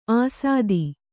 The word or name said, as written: ಅಸ್ಥಿ